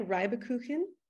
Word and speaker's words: Reibekuchen: I like Reibekuchen a lot. Oh, nice. Good pronunciation too.